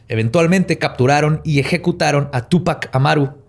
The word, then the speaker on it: Tupac: el pequeño Tupac. Era el último inca de Perú, Tupac Amaru. Órale. Tupac, porque tiene acento en la U.